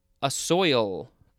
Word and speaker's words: assoil: Next, we have assoil. I think that is the correct pronunciation. It is spelled A-S-S-O-I-L.